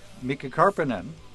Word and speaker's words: Mika Karppinen: We have the drummer, Mika Karppinen.